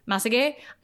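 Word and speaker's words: Massagué: Uh, the is Ivan uh, Masague. I'm probably horribly mispronouncing that. – It's probably Masage. – Masage, Ivan Masage.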